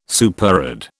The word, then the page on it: superadd: British English